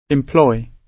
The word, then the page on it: employ: British English